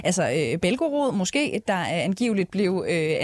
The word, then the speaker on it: Belgorod: Britiske øh, efterretninger har øh, berettet om et russisk øh, oliedepot i øh, Belgorod. Er det sådan, man udtaler det? Øh, cirka? – Altså nogenlunde.